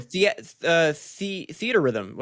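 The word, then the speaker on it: Theatrhythm: It's called Theorithium? Final Bar Line? I don't know how to pronounce that. But oh, Theater Rhythm, wasn't it? – Theater Rhythm, okay.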